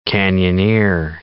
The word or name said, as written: canyoneer